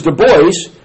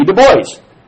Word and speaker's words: Du Bois: Du Bois, in which he corrected me. He said, "It's pronounced Du Bois."